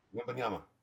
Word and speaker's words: Wembanyama: I thought that would happen because when I saw Wembanyama play, is that how you pronounce it, Wembanyama? – Wembanyama.